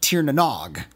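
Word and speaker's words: Tir na nÓg: Cheers, and remember, the Mystic Knights of Tir-na-Nog is pronounced Tir-na-Nog.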